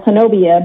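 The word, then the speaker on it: Honobia: It's spelled Hanobia, but it's pronounced Hanobi.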